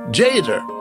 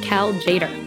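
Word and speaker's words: Cal Tjader: So you have a T that's silent and a J, but his name is Jader. – Jazz fans already know that we're talking about Cal Jader. The T is silent.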